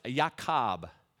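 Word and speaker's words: Yaakov (יעקב): And then Jacob's name in Hebrew is pronounced Yaakov.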